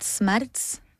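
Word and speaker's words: Smerz: We pronounce it Smerts. But it's up to you.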